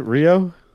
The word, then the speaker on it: Rio: Rio, was it Rio? – Yeah, Rio. – Loser. – That's it, like one syllable.